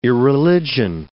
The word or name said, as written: irreligion